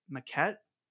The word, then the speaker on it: Maquette: and hopefully I'm saying this right, Maquette, right? The Annapurna game? – Yes, that's correct, yeah.